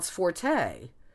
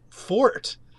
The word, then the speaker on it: forte: So in a weird way, should we really pronounce it fort? The French term of strength here, in this case, was le fort, without the T ending. If you have the E ending, it does become la forte, but that doesn't really, what I'm trying to say, that doesn't really come from the origins of the word.